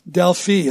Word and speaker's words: Delphi: In the mountains of central Greece is a place called Delphi or Delphi.